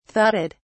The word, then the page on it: thudded: British English